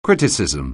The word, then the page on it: criticism: anglais